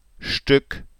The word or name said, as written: Stueck